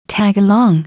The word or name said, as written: tag along